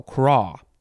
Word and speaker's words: craw: Crow? No, craw. Yeah, it's craw. The pronunciation thing says K-R-O, but there's a dot on the O, so it means you got to pronounce it a certain way. And I just read it real quick and thought it said crow. No, it's craw.